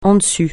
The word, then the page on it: en dessus: français